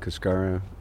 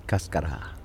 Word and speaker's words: Cascara: Yes, most Americans pronounce it Cascara. Spanish, it's Cascara.